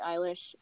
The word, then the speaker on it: Eilish: And she was like. Eilish, and I was like, eyelash? – No, she's 19.